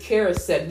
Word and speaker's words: Karasek: We have with us David Karasek.